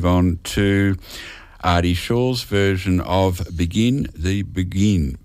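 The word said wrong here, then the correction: Beguine: Begwine. Hard to pronounce that one, Begin the Begwine.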